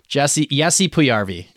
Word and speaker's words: Puljujärvi: Jesse Puljujärvi is how you actually pronounce his name, I believe.